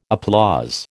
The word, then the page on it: applause: American English